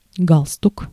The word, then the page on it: галстук: [ˈɡaɫstʊk]; galstuk